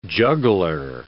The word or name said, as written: juggler